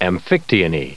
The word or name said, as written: amphictyony